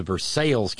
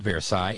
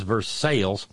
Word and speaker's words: Versailles: and through Lexington to Versailles, Kentucky. And no, by God, it is not Versailles. It is Versailles. – It is Versailles, because they'll beat your ass if you say it wrong.